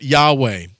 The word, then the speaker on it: Yahweh: And it is a four-letter word for God, Y-H-W-H. Most of us will pronounce it Yahweh.